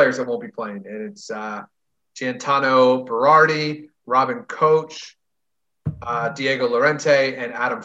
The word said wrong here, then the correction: Koch: Uh, that's name's actually pronounced cock. – Oh, I was wondering if it is because that's the German K O C H, right? – Yeah.